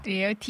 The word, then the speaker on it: drear: 드리얼, D-R-E-A-R.